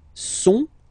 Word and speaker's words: sont: Okay, ils sont, final T not pronounced, ils sont, elles sont.